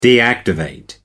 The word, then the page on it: deactivate: American English